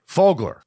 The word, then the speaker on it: Fogler: Fogler. I said it right. – Yeah, good job.